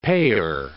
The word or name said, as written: payer